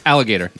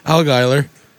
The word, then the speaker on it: Allgaier: Justin Allgaier. – Close. Alligator. – Allgaier.